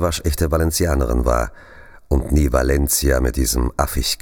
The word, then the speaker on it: Valencia: Ich sage Valencia, wie ein Castellano Valencia sagen würde. C ausgesprochen wie das TH im Englischen.